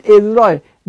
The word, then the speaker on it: عزرا: میگه دوباره به اون فرد، به یک نفری به نام عزرا، بعد میگن عزرا، ولی من چک کردم تلفظش عزرا، به عزرا دوباره اینا الهام شد.